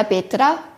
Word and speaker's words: Petra: Und es passiert mir, äh, und es ist mir sehr schnell passiert, dass die Leute zu mir gesagt haben, Ola Petra, weil sie sich dann meinen Namen merken, weil mein Name einfach ist. Das sind zwei Silben und der Name ist nur, relativ nur dazu relativ speziell.